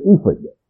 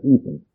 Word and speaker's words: ephod: Now let me tell you why I'm pronouncing it ephod today. Because the Bible I'm using, every one of these words is phonetically written out, and it says pronounce that ephod. But remember, whatever your pronunciation is okay. We don't know how it was pronounced, because there were no vowels. But this man says to pronounce it ephod.